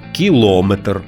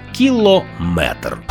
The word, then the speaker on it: кілометр: Буває, кажуть «кілометр», «сантиметр». Це неправильно. У словах на позначення «міри довжини» з компонентом «метр» завжди наголошуємо саме цю частину – «міліметр». Сантиметр, дециметр, кілометр.